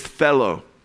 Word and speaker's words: thelo: And the Greek word in 1 Timothy 2:4, when God wills all to be saved, is thelo. Okay? T-H-E-L-O. T-H-E-L-O. Okay? Rhymes with hello, thelo.